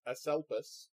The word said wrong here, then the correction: Asclepius: Is that how you pronounce it? – No. – The thing that's not Codicus. Aselpus? – No, Asclepius. – Asclepius, right? And what bothers me is Asclepius. – Asclepius, thank you.